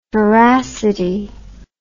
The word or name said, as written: feracity